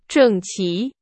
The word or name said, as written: 整齐